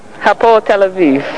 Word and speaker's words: Hapoel Tel Aviv: Hapoel Tel Aviv.